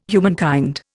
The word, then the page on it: humankind: Indian